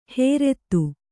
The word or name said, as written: ಹೇರೆತ್ತು